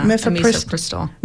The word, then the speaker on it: mifepristone: Now, one issue around that, though, is the restriction in Florida on pharmacies filling prescriptions of, it's mifepristone, right? Am I saying that right? – Yeah, mifepristone.